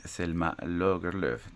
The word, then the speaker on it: Selma Lagerlöf: Eh, acabo de verificar eh, en internet la pronunciación de Selma Lagerlöf y efectivamente es Selma Lagerlöf.